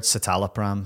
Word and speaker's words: citalopram: When I went on, I was on a citalopram. I'm not sure if that's how you say it. Citalopram? – I've heard citalopram.